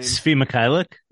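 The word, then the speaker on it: Svi Mykhailiuk: Sfi Mykhailiuk.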